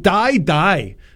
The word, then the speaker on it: Dai Dai: It's titled Die Die. Although it's not as negative as it sounds, it's spelled D-A-I-D-A-I, which would be the Italian way of saying, come on, let's go.